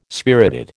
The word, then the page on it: spirited: American English